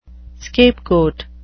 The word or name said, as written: scapegoat